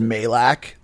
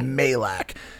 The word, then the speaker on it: Majlak: It's pronounced Majlak. The J is silent.